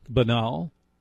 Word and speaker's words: banal: That may sound banal, and please note I pronounced it correctly.